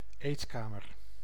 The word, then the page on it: réfectoire: France; /ʁe.fɛk.twaʁ/